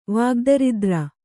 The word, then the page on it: ವಾಗ್ದರಿದ್ರ: vāgdaridra; Kannada